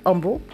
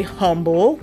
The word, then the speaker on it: humble: some people say humble, where the H is pronounced, and some people say humble, where the H is unpronounced.